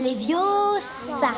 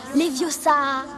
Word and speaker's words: Leviosa: C'est Leviosa. Et pas Leviosa.